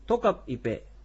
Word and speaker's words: トカピペ: トカピペ、トカプは昼でイペが食事ということで、その言葉がトカピペという発音になります。